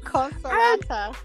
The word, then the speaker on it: Consolata: It's Consolata.